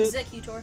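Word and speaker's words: Exeggutor: How do you say Executor. – It? Executor. Executor. Is pretty much like execute.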